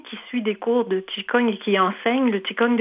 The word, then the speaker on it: Qigong: C'est que la prononciation du Qigong, ce n'est pas Qigong, c'est Qigong. – Qigong. – Oui, c'est une prononciation chinoise pour que les auditeurs puissent s'y reconnaître.